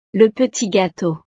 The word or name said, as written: le petit gateau